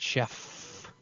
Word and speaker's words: Schaff: The three-volume Creeds of Christendom by Schaff, S-C-H-A-F-F. That's why you pronounce it Chef.